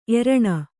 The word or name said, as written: ಏರಣ